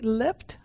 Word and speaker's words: leapt: What do I say? Leapt? How do I pronounce it? leapt. I leapt. And I was caught in the safety net of my community.